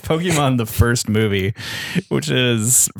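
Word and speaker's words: Pokémon: It's pronounced Pokemon.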